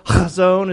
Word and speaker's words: Chazon: Chazon is the right way to say that. Can y'all say that? You got to have a little phlegm with it.